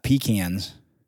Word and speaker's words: pecan: So if it's got pecans, which is how you pronounce that, it's not pecan, it's pecan. But if I look at a pecan tree,